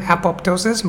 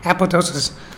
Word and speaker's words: apoptosis: So the spelling is A-P-O-P-T-O-T-O-S-I-S-I-S, but the second P is absent. So we don't pronounce it apoptosis, but apoptosis.